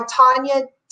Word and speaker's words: Tonja: Um, but I'm Tonja